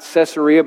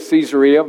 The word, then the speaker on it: Caesarea: I know I'm pronouncing that not Caesarea, but Caesarea, but that's because of some person that was guiding us on the tour who was from Israel. They kept saying Caesarea because it's named after Caesar.